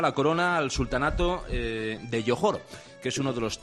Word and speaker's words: Johor: Bueno, se dice Johor, que entiendo que no estáis muy familiarizados con la zona, pero se dice Johor, aunque se escribe con J, pero es Johor, es el príncipe de Johor.